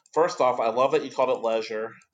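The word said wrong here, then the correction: Léger: There, it's Canadian. It's probably got some French to it. – It's Leger. I think is how they pronounce it.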